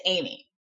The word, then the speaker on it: Ami: It took me a while to figure out how to pronounce this. – Okay. – Because it's spelled A-M-I. So I'm like, is it Amy? Is it just A-M-I? But it's Amy.